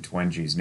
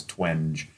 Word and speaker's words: Twenge: By the way, I want to give proper credit because you told me how to pronounce Gene Twenge's name. I always thought it was Twenge, but it's Twenge.